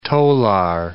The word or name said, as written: tolar